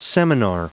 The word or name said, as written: seminar